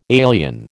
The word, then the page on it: alien: American English